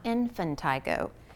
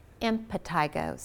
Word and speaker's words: impetigo: And this is more important in the little kids, because when they start scratching, scratching, scratching, scratching, then they get impetigo, not infantigo. Impetigo, so it's spelled I-M-P-E-T-I-G-O, impetigo, and it is usually caused by staph or strep, and it will set up shop anywhere where the skin is broken, okay?